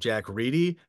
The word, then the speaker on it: Reedy: I called him Jack Reddy. And I'm happy to update it to the correct pronunciation of Jack Reedy. Because only clearly, too recently, I found out, only like about 5-6 years ago, I found out that's the real way you pronounce it.